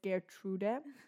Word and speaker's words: Gertrude: Was bedeutet, dass, äh, ja, es ist ein bisschen, ähm, ja, es heißt Getrude. Eigentlich muss man es Englisch aussprechen, weil Thomas meinte, es kommt aus England oder Amerika. – Mhm. Deswegen heißt es Gertrude. Und neulich hatte ich einfach meinen Lenker in der Hand.